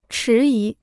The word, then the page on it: 迟疑: chí yí